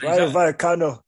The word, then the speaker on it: Rayo Vallecano: from Rayo Valenciano. – Yeah. – Oof, Rayo Vallecano.